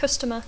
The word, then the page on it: customer: English